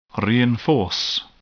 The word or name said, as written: reinforce